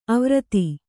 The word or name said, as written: ಆವೃತಿ